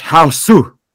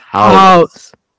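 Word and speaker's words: Hausu: You gotta watch Haosu. Or as a friend called it once, but truly it's pronounced House. House.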